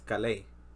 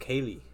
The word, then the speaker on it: Kalei: But it's spelled K-A-L-E-I. If you just say it like that in English, it would just... You know, say like how you read it in English, it's pronounced Kaylee.